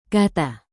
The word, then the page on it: kata: Indonesian